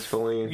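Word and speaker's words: Faline: Oh, I did not like Bambi's interaction with Failing. – Say her name one more time. – Failing? – One more time. – Feline? – Feline. – Feline. It's Feline.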